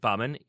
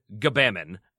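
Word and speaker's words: Gbamin: And then Jean-Philippe Baumann, he says his parents pronounce it Baumann. He says it can also be pronounced Jabama. And then third, Gabamon. And he was sort of like, you could say Gabamon if you want to. Get so Bamon. I'm going with Baumann.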